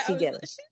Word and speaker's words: Kriégel: Did you see I put a French accent on there? I said, yeah, Kriégel.